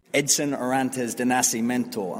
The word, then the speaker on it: Edson Arantes do Nascimento: Edson Arantes de Nascimento.